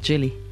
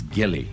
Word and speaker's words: Gilly: Our guest tonight, she says you pronounce her name with a hard G. – That's right. – What does that mean? – Gilly, not Jilly. – Not Jilly. – Yeah. – It's Gilly Apter.